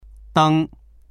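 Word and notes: deng1: Mandarin

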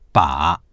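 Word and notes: 把: bǎ; ba3

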